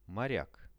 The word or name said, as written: moriak